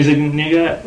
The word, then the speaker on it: Zuniga: I think it's Daphne Zuniga? – Yeah, Daphne Zuniga. – Z-U-N-I-G-A.